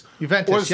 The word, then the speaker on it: Juventus: Juventus, or Zlatan Ibrahimovic's ridiculous first goal for the Los Angeles Galaxy.